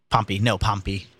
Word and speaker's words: Pompey: Pompey. – Yeah, that's how you say it. Yeah.